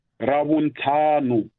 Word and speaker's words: Ravuntlhanu: Rabun Tanu is R A V U N T L. H-A-N-U. – Mm. – Rabuntanu. It's one word.